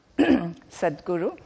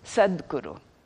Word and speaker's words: Satguru: Satguru. And when you pronounce it in Sanskrit, due to Sandhi rules, it becomes Sadguru.